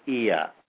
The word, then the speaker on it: Oia: And remember a place called Ia, O-I-A.